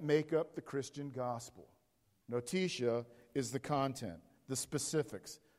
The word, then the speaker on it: notitia: If you're taking note, it's spelled N-O-T-I-T-I-A. N-O-T-I-T-I-A.